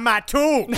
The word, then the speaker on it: Mattoon: But Marcus, you have to say it correctly. It's the Mad Gasser of Mattoon. Have you seen the gasser on that Mattoon over there? – Yes, indeed.